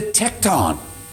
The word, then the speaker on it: tecton: He was a tecton. That's how it's pronounced.